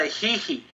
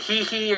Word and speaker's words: hihi: hihi. I think the hihi or the hi-hi, it's spelled H-I-H-I.